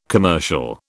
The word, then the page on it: commercial: British English